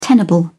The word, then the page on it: tenable: British English